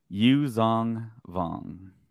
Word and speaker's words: Yuuzhan Vong: Yuuzhan Vong.